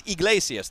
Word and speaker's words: Iglesias: It's Iglesias. There's no N there.